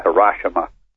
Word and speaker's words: Hiroshima: That's what we believe, researchers believe, that at least the first one, the uranium bomb over Hiroshima, incidentally, it's not Hiroshima like the new left-wing press wants to pronounce it.